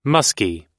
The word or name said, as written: muskie